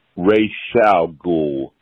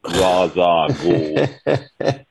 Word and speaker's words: Ra's al Ghul: It is pronounced Ra's al Ghul. Not Ra's al Ghul.